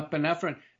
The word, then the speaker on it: epinephrine: How do you say that? Is that right? – Yeah, epinephrine.